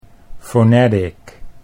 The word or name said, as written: phonetic